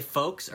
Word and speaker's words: folks: Do y'all say folks or folks? – Your what folks. – Like if you're saying, hey, what's up, folks or folks? – Folks. – Me too. Like silent L. – Yeah. – L, F-O-K-E-S.